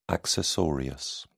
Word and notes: accessorius: British English

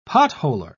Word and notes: potholer: British English

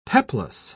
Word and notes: peplos: British English

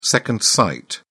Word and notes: second sight: British English

